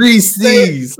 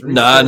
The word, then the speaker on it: Reese's: It's Reese's. – Say it correctly. Do it like I taught you. Reese's like Ramses. Reese's.